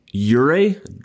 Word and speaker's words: Jure: Last Friday, I published an article with Luka's Slovenian trainer, who is, I'm mentally building up, Jure, because the J is a Y sound.